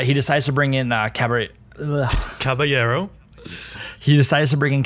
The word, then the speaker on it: Caballero: Sarri decides to make a decision to switch him out with Calabrello. Am I saying that wrong? – Willy Caballero. – Caballero.